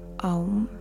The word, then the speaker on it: Aum: Aum is spelled A U M. With the first syllable, we open our mouth and we say ah.